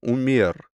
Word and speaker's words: умер: Правда, отменив заказ, он только потом понял, что так звали водителя, умер, с ударением на «е».